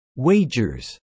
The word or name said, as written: wagers